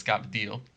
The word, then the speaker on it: cavatelli: There's cavatelli, which is known as cavadil.